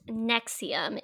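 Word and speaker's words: NXIVM: Pronounced Nexium, it definitely doesn't look like Nexium, but that's how it's pronounced, is a multi-level marketing company based in Albany, New York.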